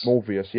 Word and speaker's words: Morbius: Morbius. Is it Morbius? I can't pronounce his name. – Morbius? – Morbius, yeah.